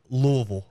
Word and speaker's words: Louisville: It's pronounced Louisville. – Okay. – Louisville. All right. Gotcha. – It's got to get stuck in the back of your throat a little bit.